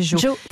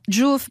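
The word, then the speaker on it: Jow: Cette application dont vous venez de nous parler, c'est Joe.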